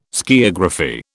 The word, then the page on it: skiagraphy: British English